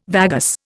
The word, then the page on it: vagus: Indian